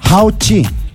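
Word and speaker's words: How chi (好吃): ¿Sabe cómo se dice delicioso? – ¿Cómo se dice? – How chi. Delicioso. How chi. – How chico. – ¿Sabe cuál? No, chico. How chi nomás.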